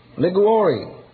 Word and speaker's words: Liguori: One noted Roman Catholic writer, Alphonsus, I'm not too good with Italian, but I think this is Liguori, L-I-G-U-O-R-I. Some Italian pronounce that for me. Liguori. You Italian? He spent a year in Italy, he says. Liguori. And he tells me that's close enough.